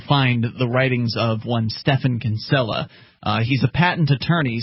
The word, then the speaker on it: Stephan: It's Stefan, but it's spelled S-T-E-P-H-A-N, Kinsella, K-I-N-S-E-L-L-A.